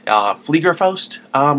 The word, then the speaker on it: Fliegerfaust: And I'm sure I ruined that, and you would think, being German myself with my German heritage, I'd be able to pronounce it correctly. Fliegerfaust might be a better pronunciation,